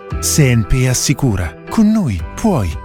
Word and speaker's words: CNP: Si legge SNP, si scrive CNP.